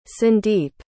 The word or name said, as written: sundeep